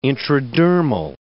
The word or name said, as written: intradermal